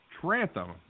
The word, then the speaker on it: Trantham: Brady Trantham.